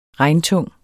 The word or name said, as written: regntung